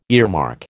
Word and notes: earmark: American English

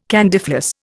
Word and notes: candyfloss: Indian

